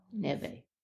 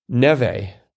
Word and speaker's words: Neve: 19 Across, Campbell of Scream, Neve. – Neve. – Neve.